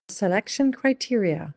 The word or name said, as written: The selection criteria